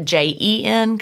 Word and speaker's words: Jen: I get J E N because sometimes people think it's like Jennifer, but it's Jen for Virginia, like gin and tonic.